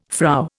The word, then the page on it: Frau: Indian